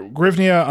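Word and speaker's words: hryvnia: In English, I think it's Grivnia. Grivnia is good. Yeah, Grivnia is very good.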